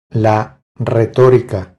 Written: La retorica

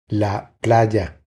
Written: La playa

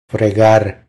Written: Fregar

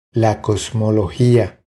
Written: La cosmologia

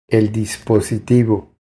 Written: El dispositivo

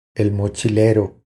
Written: El mochilero

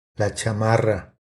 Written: La chamarra

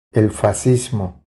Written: El fascismo

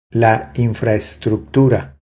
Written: La infraestructura

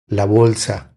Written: La bolsa

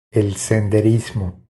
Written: El senderismo

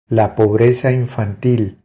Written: La pobreza infantil

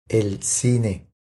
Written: El cine

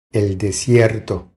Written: El desierto

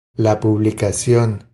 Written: La publicacion

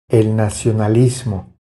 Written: El nacionalismo

